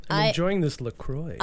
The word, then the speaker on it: LaCroix: LaCroix. – LaCroix.